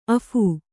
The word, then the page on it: ಅಫೂ: Kannada